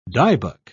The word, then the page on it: dybbuk: British English